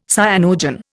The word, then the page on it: cyanogen: Indian